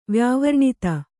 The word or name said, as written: ವ್ಯಾವರ್ಣಿತ